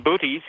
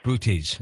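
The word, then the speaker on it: Boötes: Epsilon Bootes? Something, is that how it's pronounced? – Epsilon Bootes, yes. – It's Bootes. I say Bootes, you say Bootes.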